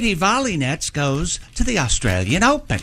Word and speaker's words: Volynets: Volley Nets, V O L Y N E T S. – Wow. – She says, though, for the convenience of English speakers, she does pronounce it Volley Nets, but she is from, she has Ukrainian parents, born in the United States.